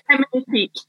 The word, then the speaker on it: Femnifique: Feminifique, yes. It's like magnifique, but with fem.